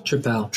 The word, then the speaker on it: Tripel: I had I tried New Belgium's, I'm going to mispronounce this, but it's triple. – Tripel. – Tripel.